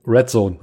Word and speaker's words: RDZN: RDZN, wie, wie spricht man das aus? – Red Zone. – Red, Red, Zone. Red Zone. Ist, ist quasi Zone. so eine der Zone-Schreibweise für Red Zone.